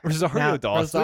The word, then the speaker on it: Rosario Dawson: Rosario Dawson.